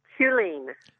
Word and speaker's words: Huelene: Helene. – Oh, how do you spell that? – H-U-E-L-E-N-E.